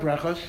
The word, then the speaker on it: Brachot: Do you want to explain what Sheva Brachot are? – Okay, okay. So the truth is, any sitter will tell you the word Sheva Brachos or Brachot, depending how you pronounce it, just means seven blessings.